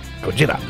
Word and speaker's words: Gojira: We heard Gojira. Flying Whales by, is that how you say it? Gojira. – Gojira.